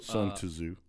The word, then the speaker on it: Sun Tzu: Sun Tzu. – That's the correct pronunciation.